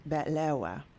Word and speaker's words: betlawa: Of course, but at Cairo, you should know, we pronounce the dessert betlawa. – Betlawa? – Yes. We Egyptians put a little spin on language. It's like an accent.